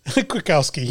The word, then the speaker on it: Kwiatkowski: Husky defensive coordinator Pete, see if I can pronounce this, Kwiatkowski. Did I say that right? You're the Husky. – Kwiatkowski, yes.